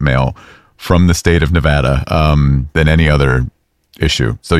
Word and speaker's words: Nevada: Because a lot of politicians would say Nevada. – Wrong. – And that is wrong. – It is Nevada. And I had to train myself to say Nevapple so I could pronounce the A right.